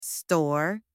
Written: store

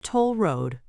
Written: toll road

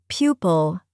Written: pupil

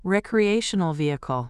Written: recreational vehicle

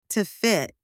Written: to fit

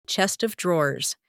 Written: chest of drawers

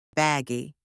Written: baggy

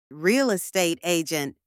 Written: real estate agent

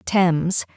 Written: Thames

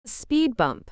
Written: speed bump

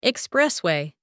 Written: expressway

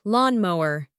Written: lawn mower

